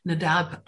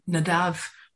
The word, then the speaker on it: Nadab: And that something was the bait, which is the final letter, B-E-I-T. Some English translations refer to Nadab as Nadav, because the final letter in Hebrew, the bait, can be pronounced as vait or bait as a soft sound or whatever. So I might occasionally call him Nadav and Avihu, or it could be Nadab and Avihu, but it's basically the same name.